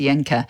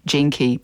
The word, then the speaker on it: Jenke: My surname, Jenke, Jenke, it's actually a German surname, and the J in German is softer, silent, almost, Jenke. But it's just been Kiwi-ized to Jenke. But that's okay. Most people spell it J-E-N-K or I, J-E-N-K-I, but it's J-E-N-K-E.